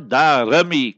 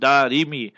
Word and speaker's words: Darimi: Darimi, people pronounce it wrong. It's not Darimi. Kasra on the Ra. Kzair on the Ra. Rawahu Darimi.